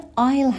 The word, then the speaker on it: aisle: کلمه aisle هم که اس داخل این کلمه تلفظ نمیشه به معنای راه رو هستش.